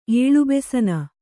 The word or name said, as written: ಏಳುಬೆಸನ